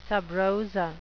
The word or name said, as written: sub rosa